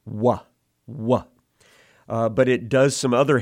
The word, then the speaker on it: Waw: If you listen to how I pronounce it here, Wah, Wah, that's the sound. You can hear the shortened form of U, And so this letter sometimes represents that sound as a vowel in the Hebrew language. But it also stands in for the tightening of the mouth in that same form where we go, oh, oh, oh. So the long O sound as well.